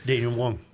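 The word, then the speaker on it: dénouement: I thought it was denouement. – Denouement. Okay. You don't pronounce the ENT. You would say denouement. – Denouement. – It's denouement.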